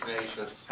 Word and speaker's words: Kneizys: Oh, I'm Steve Tenacious. Okay. I live in Muirfield. – Can you spell your last name? – K-N-E-I-Z-Y-S. It rhymes with Tenacious.